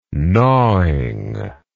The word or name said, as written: gnawing